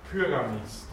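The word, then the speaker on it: πυραμίς: Betonung mit Y. Pyramis, das ist, das kennen wir als griechisches Weinwort, die Pyramide.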